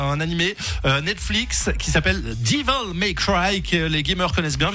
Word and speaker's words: Devil May Cry: On peut prononcer correctement. Devil May Cry.